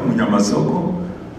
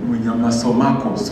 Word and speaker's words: Munyamasoko: I'm Reverend Gato Munyamasoko. I like the way they pronounce the name. I remember my friend Gordon was saying, Munyamasomako, so they did well.